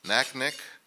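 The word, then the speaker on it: Naknek: She was raised in rural Alaska Native villages, including Koyukuk, Kaniganek, Naknik, Nikolai, and Selowik. How'd I do in the pronunciation there? Good.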